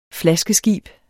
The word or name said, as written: flaskeskib